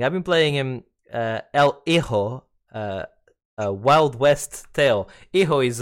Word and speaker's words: hijo: Hijo, yeah, Hijo. Yeah, that is, the J Hijo. Is like, like Juan, Hijo.